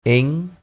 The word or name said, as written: en